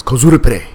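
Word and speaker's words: kozurupre (コスプレ): And you know, it's kozurupre. That's how you pronounce kozurupre.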